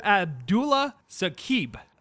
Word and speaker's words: Abdullah Saqib: And yeah, it's Abdullah Saqib, but you pronounce it quite accurately anyways.